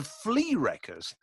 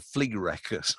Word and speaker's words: Flerekkers: ever, because it's spelled F L E R E K K E R S. How weird is that? the Flea Wreckers.